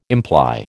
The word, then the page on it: imply: American English